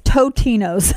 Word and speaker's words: Totino's: I'll get ice cream that's not Ben & Jerry's, or I get the Totino's, which until, Tostinos. Like, literally, yeah, last year I thought was Tostino's. It's not. – Really? – It's Totino's. Totino's. You have to sound like you're saying it wrong.